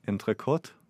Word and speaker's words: entrecote: They only really have four dishes, as I could see, which includes fish and entrecote. Am I saying that correctly? – Yeah. Very good.